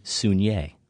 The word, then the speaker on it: Saulnier: I believe, I looked this up, it's Jeremy Saulnier. – Oh, wow. – So we've all been wrong.